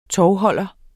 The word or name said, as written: tovholder